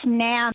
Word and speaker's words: NAMM: Is it pronounced NAMM? NAMM? It's it's NAMM.